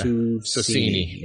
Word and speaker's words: Susini: Sure, I didn't say that right. – Arjuna. – Yeah, Susini. – Yeah. That's what I would have gone with.